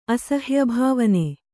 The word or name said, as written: ಅಸಹ್ಯಭಾವನೆ